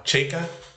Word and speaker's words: Chaika: So this is, uh, make sure I'm saying it right now, Chaika? – Yes, beautiful.